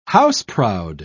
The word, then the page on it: house-proud: British English